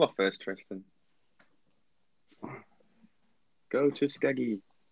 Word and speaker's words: Tuskegee: It's spelled Tusk, like animal tusk, like T-U-S-K. Then an E, and then G-E-E. So it's like Tusk, E, Tuskegee.